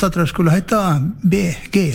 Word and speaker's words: Bee Gees: Og så har du at du skriver det slik som du uttaler det, så ble det Bee Gees. – Ja. – Det ikke det? Så det egentlig BG på en annen måte.